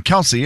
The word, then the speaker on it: Kelce: and everybody's been calling him Kelce, and it is spelled K-E-L-C-E. – Right. – Everyone's called us Kelce for so long that we just haven't really corrected it.